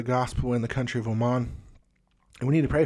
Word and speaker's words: Oman: And many people, there's lots of jokes, oh man, because that's how it's spelled, O-M-A-N, oh man, Oman, Oman is what it's called.